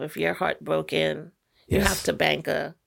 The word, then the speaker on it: Tabanka: And then the other one is Tabanka. Is that Tabanka. – How you say it? T-A-B-A-N-K-A. – I guess.